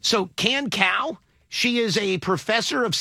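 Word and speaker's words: Kan Cao: K-A-N is the first name. – Okay. – The last name is C-A-O. How do you pronounce that? Cow? – Yeah. – Can Cow? – Sure.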